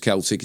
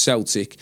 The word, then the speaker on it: Celtic: Celtic is spelt the same way as you would say Celtic, but it's not pronounced Celtic, it's pronounced Celtic.